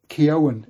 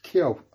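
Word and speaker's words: Keoghan: So it's Keown, it is. Barry Keown. I always Keogh. Maybe it's just the way I've heard it. Maybe it's the Irish pronunciation.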